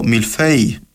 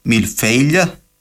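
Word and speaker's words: mille-feuille: Ξέρετε ότι το μιλφέι, το γνωστό έδεσμα, προφέρετουν κάποτε μιλφέιλια με λάμδα στο τέλος και έπαθε γεϊσμό και κατέληξε να προφέρεται μιλφέι με γάμμα δηλαδή.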